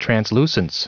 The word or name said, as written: translucence